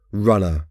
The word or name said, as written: runner